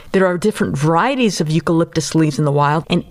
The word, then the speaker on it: eucalyptus: Eucalyptus leaves. – That's correct. The only food koalas eat, which happens to be poisonous to most animals, are eucalyptus leaves.